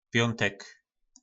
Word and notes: Pjontek: po nasimu

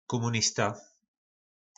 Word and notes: komunista: po našimu

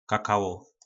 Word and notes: kakalo: po našimu

